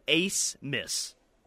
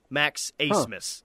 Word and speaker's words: Abmas: Last name is spelled ABMAS, but it's pronounced Ace Miss. Max Asmus.